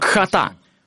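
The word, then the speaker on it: хата (חטא): Тут надо произносить гортанный звук «хата». «Хата». Так оно произносится, как будто собираешься сплюнуть. «Хата».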